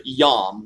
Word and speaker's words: yom: The word that's used in Genesis for day is translated from the Hebrew word yom, Y-O-M, as we would pronounce it.